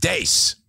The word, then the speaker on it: Deese: And his last name rhymes with race, so you can correct your pronunciation. Steve Deese.